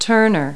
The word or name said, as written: turner